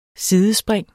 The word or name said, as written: sidespring